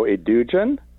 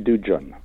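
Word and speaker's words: Edugyan: It's a soft G. So Edugen, it's Essie Edugen. And her parents are from West Africa, so it's actually a Ghanaian name. So okay. It's Edugen.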